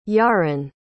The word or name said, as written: Yaron